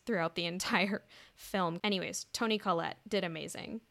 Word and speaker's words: Toni Collette: The father is a psychologist, and Toni Collette's character. Am I saying her name right? – Yeah, Toni Collette. You did wonderfully.